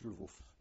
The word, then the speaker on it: Lwów: It's pronounced, in Polish, it's pronounced Lwów. – Lwów.